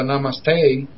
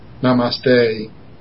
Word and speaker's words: Namaste: Well, they always pronounce it Namaste. – Namaste. – Like it ends in, imagine the letter ends in A-Y, so they always say Namaste.